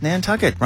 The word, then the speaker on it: Nantucket: Island off Cape Cod, East Coast, Nantucket. Rhymes with bucket.